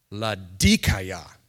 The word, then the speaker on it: Laodicea: By the way, this is pronounced Laodicea. But I'm not going to say that. Because I don't feel like saying Laodicea every time I say this. It's Laodicea.